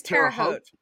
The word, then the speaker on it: Terre Haute: It's Terre Haute.